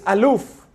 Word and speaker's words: Aleph: The letter Aleph, the way it's pronounced, is Aluf, which means the wondrous one, the strong one.